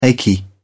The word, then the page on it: achy: British English